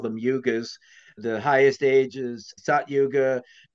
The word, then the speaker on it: yugas: Is it yugas? Am I saying that right? – The yugas.